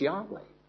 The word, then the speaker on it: Yahweh: It is the name of God, and it is properly pronounced Yahweh.